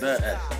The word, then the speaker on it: The Essence: Well, it's actually pronounced The Essence. The Essence. With an A.